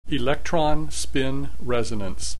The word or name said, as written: electron spin resonance